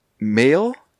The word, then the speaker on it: Maol: So I guess Moyle is a narrow body of water, a corruption of the of the word male, M A O L. And so Moyle okay. became like, but it actually should be pronounced male, but right.